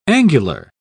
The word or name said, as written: angular